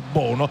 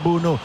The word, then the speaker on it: Bounou: ha 2 pronunce Bounou, o Bounou o Bono così come ha scritto sulla maglietta, però insomma la pronuncia in lingua marocchina è Bounou.